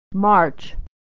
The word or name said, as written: March